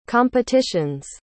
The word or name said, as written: competition's